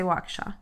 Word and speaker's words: Waukesha: I say Waukesha. and that's the right way to say it.